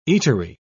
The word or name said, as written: eatery